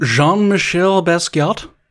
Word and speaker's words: Jean-Michel Basquiat: There's also the Brooklyn artist that I don't know how to pronounce his last name. – Jean-Michel Basquiat. – Very good.